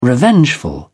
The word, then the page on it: revengeful: British English